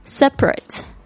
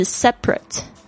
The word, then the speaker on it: separate: Number five， separate，在这边我们讲的是形容词，形容词的发音是 separate，它那个中间那个 A 就没有发出来声音，但是如果你特别要讲这个动词就是 separate， separate 它有这个分开，但是它也有指就是两个人分居的意思， separate， separate 形容词， separate 是动词分开。